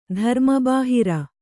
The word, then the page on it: ಧರ್ಮಬಾಹಿರ: kannada